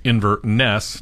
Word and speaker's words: Inverness: You will learn Inverness is pronounced Inverness, not the way it's pronounced in Alabama.